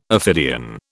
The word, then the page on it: ophidian: British English